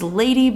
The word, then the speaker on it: LVDY: Again, our name is Lady, but it is spelled LVDY.